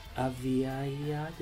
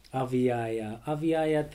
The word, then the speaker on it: Aviaja: Aviaya, did I say that right? – Yes, it was very nice. Yeah. – Aviaya, thank you so much for joining me here.